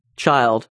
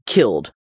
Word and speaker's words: cild: So if you see C-I-L-D, it's chilled, child, not killed in Anglo-Saxon.